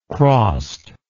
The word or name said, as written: crossed